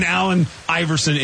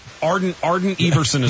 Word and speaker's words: Allen Iverson: During an Allen Iverson interview. You know, he's botching it. – Get his name right? – Arden Iverson is what he called. Arden Iverson's bad.